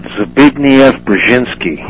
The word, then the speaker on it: Zbigniew Brzezinski: Along with how do you say Carter's National Security Advisor's name? I always have a tough time with that one. Zabrinsky? – I've been pronouncing it Zbigniew Brzezinski.